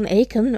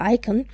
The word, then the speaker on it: Aiken: ist immer Joan Aiken oder Aiken. Ich glaube, sie wird Aiken ausgesprochen. Tatsächlich wahrscheinlich deshalb, weil sie ganz viel in den 90ern geschrieben hat.